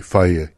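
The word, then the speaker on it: Fage: that's how you pronounce it, by the way, Faye yogurt.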